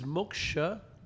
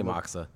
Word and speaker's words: Moksa: So, is Moksha the appropriate pronunciation? – Or I always say Moksa.